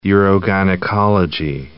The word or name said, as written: urogynecology